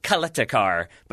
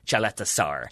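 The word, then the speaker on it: Ćaleta-Car: It's Caleta-Car. So you would think Caleta-Car, but from what I understood from the French commentary, Çaleta-Sar.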